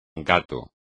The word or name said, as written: gato